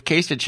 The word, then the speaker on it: Kasich: it, is it Kasich or Kasich? I say I it both Kasich ways. Probably, I think I'm remembering that Kasich is probably the correct pronunciation. I was the one saying Kasich.